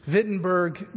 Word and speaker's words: Wittenberg: The location is Wittenberg, Germany. It's spelled with a W, but I'm just letting you know it's pronounced with a V.